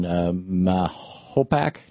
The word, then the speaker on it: Mahopac: John in Mahopac, New York.